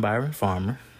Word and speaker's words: Byron Farmer: First of all my social media except for Snapchat is I am Byron Farmer.